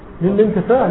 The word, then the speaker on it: للانتفاع: لا، هنا الألف هنا همزة، هنا همزة قطع، همزة وصل، لا تنطق. قد حان للانتفاع، لا تنطق الهمزة.